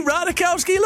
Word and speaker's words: Ratajkowski: Emily Ratajkowski, look, hey, that's how you say it.